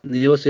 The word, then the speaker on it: Nealis: N-E-A-L-I-S. – Dylan Niels, yeah. – Niels, yep.